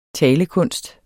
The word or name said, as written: talekunst